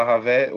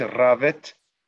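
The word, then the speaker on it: Rawet: Ravé, o pessoal no Brasil fala Ravé, o nome seria pronunciado, pronunciado corretamente Ravet, mas todo mundo conhece como Ravé.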